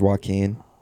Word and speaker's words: Joaquin: All right, so new Joker movie rumored to be coming out with Jaquan Phoenix. I think that's how you pronounce his name, Jaquan. – I think it's Joaquin. – Joaquin? Yeah. Oh, it is.